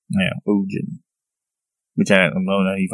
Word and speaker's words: Ogen: And then the Vikinger, is Ogen. It's O-G-E-N. Kind of like Odin, but with a G.